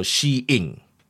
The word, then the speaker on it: Shein: It says the way you should call it, it's S-H-E, like it's she, right? – She. – And I-N. – Okay. – So you need to separate she and in. So it will be like Shane. – So it's two syllable word, nice and easy. S-H-E, she, and In. So Shein.